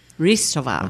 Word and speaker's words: Hristova: And have Bella Hristova, violin. Am I saying her name right? – Hristova. – Yeah. Hristova. – Ristova.